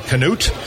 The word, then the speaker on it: Conneaut: Well, look at Conneaut, Ohio, spelled C-O-N-N-E-A-U-T. People say, what is that, Canute?